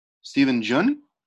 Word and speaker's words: Steven Yeun: I'm just trying to make sure I'm saying his name right. Steven Yeun? Okay. According to the Wikipedia, for Minari.